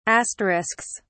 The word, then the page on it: asterisks: British English